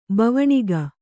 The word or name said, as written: ಭವನಿಕೆ